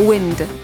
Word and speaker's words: wind: Tę samą opisownię posiada również wyraz, który oznacza wiatr. Wymowa jest jednak nieco inna. Rzeczownik wymawia się jako wind.